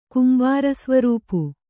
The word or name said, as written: ಕುಂಬಾರಸ್ವರೂಪು